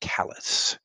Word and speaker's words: Calais: In the uh, in the Olivier version here, Calais is pronounced Calais.